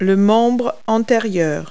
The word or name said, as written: foreleg